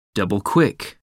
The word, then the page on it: double-quick: American English